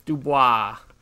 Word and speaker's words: Dubois: Dubois.